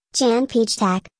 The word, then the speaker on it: Jan Pietrzak: Jan Petersack, pronounced Jan Pietrzak, born April 26,